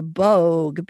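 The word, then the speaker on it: Böögg: One is called Sexalointen, and it's spelled S-E-C-H-S-E-L-A-U-T-E-N. It's celebrated in Zurich in Switzerland, and there's this 12-foot-tall creature called the Boog, B-O-O-G-G.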